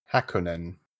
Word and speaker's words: Hakone: We also saw in the trailer what Forza calls Circuit, and I'm probably going to pronounce this incorrectly, Hakonen? H-A-K-O-N-E. – Hakonen.